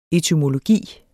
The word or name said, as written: etymologi